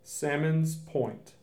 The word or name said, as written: Sammons Point